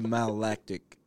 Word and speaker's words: malolactic: So for the layman, you gotta what malolactic is. – Yeah, am I even saying it properly? – Malolactic.